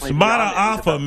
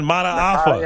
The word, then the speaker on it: Mata'afa: If we had the All-America team right now, this guy named Hercules Matapa, and I think I butchered his last name. – It's Mata'afa, man. Mata'afa.